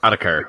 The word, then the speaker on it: Ottokar: Otacur. We're going to go with Otacur. That will be how we are pronouncing that one.